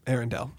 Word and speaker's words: Arendelle: Arendelle.